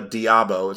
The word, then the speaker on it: d'Abo: Olivia d'Abo